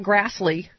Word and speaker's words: Grassley: Senator Chuck Grassley. – Rhymes with ass-ly. – Yes, it does.